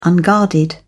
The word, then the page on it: unguarded: British English